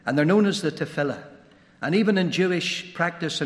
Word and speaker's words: Tefillah: First of all, they would say, "You pronounced it wrong. It's Tefillah," or Tefillah, T-E-F-I-L-L-A-H.